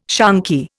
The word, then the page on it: shonky: Indian